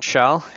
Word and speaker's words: Kjell: I'm John Schell, even though the last name is spelled KJ, it's pronounced SH.